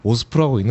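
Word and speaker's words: OSPO: OSPO를 오스포라고 읽는지 몰랐는데 계속 궁금했거든요.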